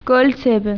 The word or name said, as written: alfombra